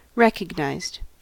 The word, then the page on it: recognized: American English